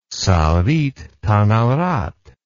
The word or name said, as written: saritthanarat